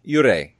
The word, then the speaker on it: yurei: Yurei.